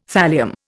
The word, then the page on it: thallium: Indian